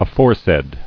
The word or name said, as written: Aforesaid